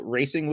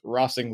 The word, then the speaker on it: Racing: The fact that NWSL had the big announcement this morning that Louisville will be what they would want us to call Racing Louisville, but clearly has to be pronounced Rossing Louisville. if they're going to take a soccer tradition from another country, they're stuck with the Spanish pronunciation, I think.